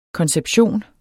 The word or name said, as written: konception